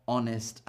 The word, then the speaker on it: honest: And sometimes H is not pronounced at the beginning of words. Sometimes H is not pronounced at the beginning of words. Things like our, honest, and air. But there are a lot of words where we do as well, like horse and honey.